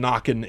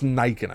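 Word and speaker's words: Nøkken: I believe you mentioned Nokken in passing. – Okay. – Pronounced Nyken, I believe, actually.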